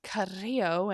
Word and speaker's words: Carrillo: You're supposed to pronounce that Carrillo and roll your R's.